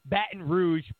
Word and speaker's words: Baton Rouge: It goes, powerful image of protester being detained near HQ of the Boston Rogue Police Department. Baton Rouge Police Department. I hate when I mispronounce stuff.